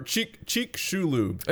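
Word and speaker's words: Chicxulub: Or Cheek Shulub. – There you go. – If you want to say it correctly.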